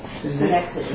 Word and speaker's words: synecdoche: You know, it's called a I'm not sure exactly how to pronounce it. Synecdoche, is that right? – Synecdoche. – Synecdoche? – Right. – Okay. S-Y-N, I know how to spell it. Mm. And it ends with D-O-C-H-E.